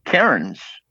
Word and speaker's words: Cairns: And I said, I've been to a little place up on the coast, the Great Barrier Reef area, to go snorkeling in Cairns. And he goes, Cairns, and it's spelled C-A-I-R-N-S. – Okay. – And as Canadians, we'd pronounce that as Cairns.